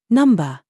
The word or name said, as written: NOUN